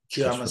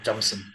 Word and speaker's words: Jameson: Jameson. – For those who listen and question pronunciation, Jameson.